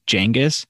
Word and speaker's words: Genghis: The topic of our podcast today, Genghis Khan, or as you enlightened me, Genghis Khan. – Yeah, so that's how it is most similarly pronounced to how the Mongols probably pronounce it, is Genghis. But then, you know, we've all said Genghis.